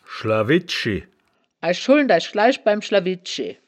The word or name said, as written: schlawidschi